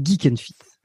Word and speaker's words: Geek and Fit: Est-ce que tu, tu, dis Jack and Fit Est-ce que tu fais exprès de le prononcer comme ça Ou est-ce que. Parce qu'on dit Geek and Fit.